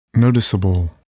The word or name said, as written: noticeable